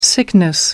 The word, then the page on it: sickness: American English